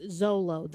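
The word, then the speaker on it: Xolo: Yeah, but it's pronounced Zolo. The Zolo dog, little dog, I don't know.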